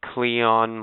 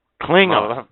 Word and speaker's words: Klingon: Cleon molesting motherfucker. – What? – You Cleon molesting. – Klingon.